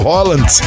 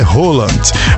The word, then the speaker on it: Haaland: Você lembra do Haaland, como nós costumamos chamar aqui no Brasil, sendo que a pronúncia correta é Haaland.